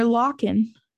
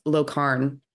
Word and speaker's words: Lochan: No, her last name is, it's spelled L-O-C-H-A-N. – Oh, I was calling her Locken. – Yeah, no, it's Locarn.